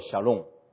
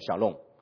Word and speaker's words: shalom: 这个词希伯来文叫做 shalom，希伯来文读成 shalom。